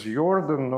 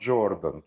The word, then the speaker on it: Jordan: And why you didn't hear only little about him, I emphasize his name is Jordan, not Jordan. Jordan would be American or British pronunciation, but he was German. So J in German is Y.